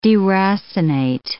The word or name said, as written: deracinate